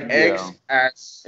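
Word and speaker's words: X Æ: Well, the AE stands for Ash. Well, that's how you pronounce it. It's like X, Ash.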